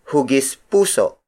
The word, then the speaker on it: hugis puso: Another example is hugis puso. Hugis puso. Hugis puso. Hugis puso is spelled as H-U-G-I-S hyphen P-U-S-O.